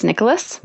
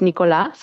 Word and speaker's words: Nicolas: I actually end up saying Nicholas a lot in interviews too, because in English it's always Nicholas. – Right. – And in French it's Nicolas.